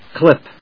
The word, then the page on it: clip: klíp